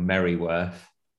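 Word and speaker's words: Mereworth: So my mum was in a small, very small village called Merryworth. She still is, and which is not spelt Merryworth; it's spelt Mearworth, but that.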